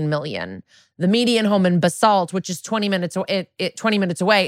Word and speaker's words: Basalt: Like the guy that was like telling us, he goes, the place is called Basalt. And I remember now how to say it because they said Lori Kilmartin goes, it sounds like a salt.